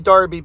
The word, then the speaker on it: Derby: Yes, I know it's supposed to be Derby, but you know what? Somebody find me another instance of an E in that word that's pronounced like that A, and then maybe we can talk about it.